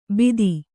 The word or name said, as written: ಬಿತ್ತಿ